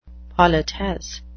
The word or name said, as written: politesse